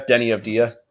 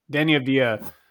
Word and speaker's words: Deni Avdija: Of Dia, Deni Avdija. – It's a Deni Avdija.